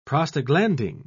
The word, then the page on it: prostaglandin: British English